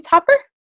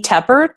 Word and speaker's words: Tepper: Is it Amy Topper? – You know, actually, it's Amy Tepper, Tepper like pepper.